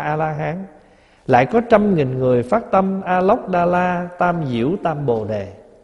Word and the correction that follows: A-Nậu-Đa-La: Mình thường đọc là A-Nậu-Đa-La Tam Diệu Tam Bồ Đề đó, nghĩa là vô thượng chánh đẳng chánh giác.